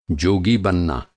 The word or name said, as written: जोगी